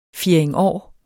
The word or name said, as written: fjerdingår